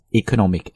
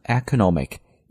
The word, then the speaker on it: economic: Okay, so Top Economic Advisor。 Now, 经济的这个单词我们倒是可以讲一讲它的发音了。很多同学呢能听过这个单词读成 economic, 一点问题都没有，但同样呢它也可以读成 economic, 都是 OK 的，所以这个 E 呢既可以读 a 这个音，也可以读 it 这个音， so economic or economic 都是完全没有问题的。